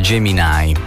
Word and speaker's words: Gemini: Gemini Man, in realtà nel film sentiamo pronunciare Gemini, chiaramente con eh, la pronuncia esatta.